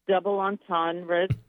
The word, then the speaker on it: double entendre: She was always sweet, and she did had a lot of double entendres.